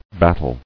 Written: Battler

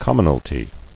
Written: Commonalties

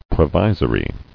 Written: Provisorily

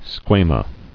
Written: Squamae

